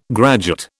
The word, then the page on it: graduate: British English